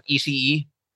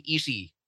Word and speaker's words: Ishii: It's Ishii, not Ishii. So no, even people on AEW TV get it wrong. It's actually Ishii. It's just kind of anglicized kaya ganun yung spelling, Ishii.